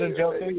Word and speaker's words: Njoku: Njoku. Njoku. – David Njoku, yeah.